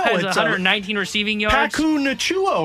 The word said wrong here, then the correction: Puka Nacua: Puka Nacua, I think yeah.